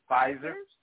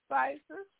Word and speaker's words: Pfizer: Pfizer. – Pfizer, yes.